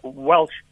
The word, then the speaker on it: Welch: How do you say it? Welsh. – We- Welsh. Welsh. W E L C H.